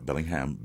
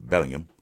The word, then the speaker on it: Bellingham: And then you have Harry Kane and Jude Bellingham, not Bellingham, Bellingham.